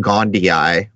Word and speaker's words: gondii: But anyhow, Jason, as you pick up, I think the Latin you're about to have to say is pronounced Gondii with the double I on the end there.